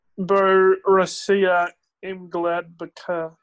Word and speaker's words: Borussia Mönchengladbach: Next match is Borussia Mönchengladbach and Bayer Leverkusen. – Can you pronounce that again for me, please? – Borussia Mönchengladbach.